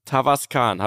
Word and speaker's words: Tavascan: Und dann ging es zu meiner allerersten Autopremiere, Auto-Weltpremiere vom Cupra Tavascan. Habe ich das jetzt richtig das gesagt? Das hast du sehr gut ausgesprochen.